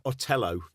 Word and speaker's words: Otello: It's not called Verdi's Othello. It's called Verdi's Otello.